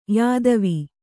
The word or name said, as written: ಯೌತವ